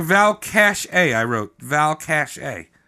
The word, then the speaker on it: Valcartier: And then he got his crony contractors to construct a new fort at Valcartier. Which I looked up, and that's how you say it. It's Valcartier.